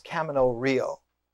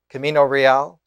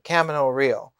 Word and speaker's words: Camino Real: Indeed, when Tennessee Williams, in one of his great visionary qualities, wrote the play, it's pronounced Camino Real, Camino Real, the Royal Road, but he liked the American pronunciation, Camino Real.